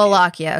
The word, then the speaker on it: Wallachia: Yeah, so it's Prince Dracula of Wallachia. – Wallachia. Okay, so W sound like V's. Got it. – Yes. It's, yeah, very German.